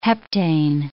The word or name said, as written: heptane